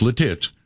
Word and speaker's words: Lititz: It's pronounced Lititz.